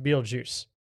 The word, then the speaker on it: Betelgeuse: It's the star is pronounced Beetlejuice. It's spelled Betelgeuse.